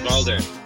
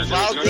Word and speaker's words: Uvalde: Uvalde. Yeah, Uvalde.